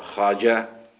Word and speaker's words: خواجة: قال الشيخ العظيم خاجة نصير الدين الطوسي، الخاجة يعني السيد على ما يبدو العالم، في بعض بلدانها الخواجة هي خاجة مثل خاهر، الواو والألف إذا جاءت كما بالفارسية، الواو إذا جاءت بعدها ألف لا تلفظ كأنها خاهر، خاهر خواهر، هذه أيضا خاجة، خاء واو ألف بمعنى السيد، في بعض بلداننا الخواجة في مصر في لبنان.